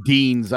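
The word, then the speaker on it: Deines: They're saying Deans. I've heard it pronounced Deans, Adam okay.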